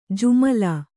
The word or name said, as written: ಜುಮಲಾ